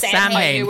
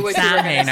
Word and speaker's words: Samhain: is it gives Americans an opportunity to really badly mispronounce Samhain. – Yes, Samhain. Samhain, I think you mean.